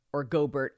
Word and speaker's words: Gobert: Rudy Gobert, Gobert, I don't know how he says his name.